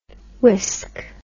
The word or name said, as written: wisk